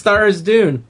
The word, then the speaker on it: Tharizdun: I like how all three of us have named that god and pronounced it differently. – It's Tharizdun.